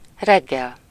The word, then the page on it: reggel: [rɛɡ.ɡɛl]; /ˈrɛɡːɛl/